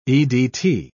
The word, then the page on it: edt: British English